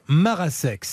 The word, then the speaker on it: Marasek: On prononce comme ça, Moussa ? – C'est ça, oui, exactement. – Monsieur Philippe Marasek.